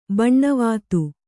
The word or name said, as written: ಬೋನವೆತ್ತು